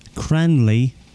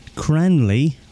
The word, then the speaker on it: Cranleigh: That is Seagull, Radio Fax, that's F-A-X, The Forge, Cranley, which is spelled C-R-A-N-L-E-I-G-H, Surrey, England, and the postcode is GU67BG, that's Golf Uniform 6, and 7 Bravo Golf.